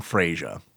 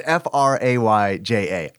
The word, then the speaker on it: Freya: I called him Freya. – Freya. – Because it's Frayja.